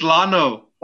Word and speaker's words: Llano: How do you say it? – It's Llano.